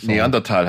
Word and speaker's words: Neanderthal: Und so meine Highlights sind wirklich der eingangs erwähnte Titelsong, dann Neanderthal. Herr Englischlehrer, wie spricht man das genau aus? Neanderthal, glaube ich. – Neanderthal heißt es.